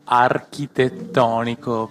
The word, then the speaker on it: architettonico: Allora, tredicesima puntata, siamo qua appunto con due ospiti del complesso architeutonico, giusto? Ho detto no. – No, si pronuncia architettonico, perché okay. Il CH noi lo pronunciamo col suono.